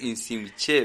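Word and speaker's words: Iximché: La tierra de Insimiche, ¿cómo se dice? – Exinche, algo así. ¿Cómo? – Sí. Muy bien, no podemos decir muy bien, pero yo creo que es Insimche, ¿verdad? – Sí,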